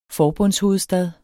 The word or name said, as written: forbundshovedstad